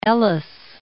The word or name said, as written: Ellis